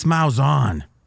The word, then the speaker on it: Malzahn: This Malzahn. Is that how you say his name? – Yes. yes.